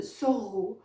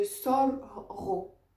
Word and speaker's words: sorgho: le soro. Donc le sorro, d'accord Ça s'écrit avec S-O-R-G-H-O.